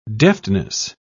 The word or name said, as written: deftness